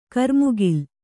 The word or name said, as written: ಕ್ರಿಮಿಕುಲ